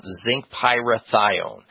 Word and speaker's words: zinc pyrithione: Just, you know, phonetically sound it out. Zinc pyrithione.